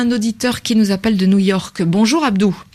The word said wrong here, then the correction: Abdoul: Abdoul, pardon, j'écorche votre nom.